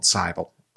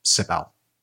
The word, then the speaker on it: Cybele: The mother god was called Cybele or Cybele. – Everyone pronounces things different. This is one thing that we struggle with on this, because I did look up these pronunciations and everyone pronounces them different.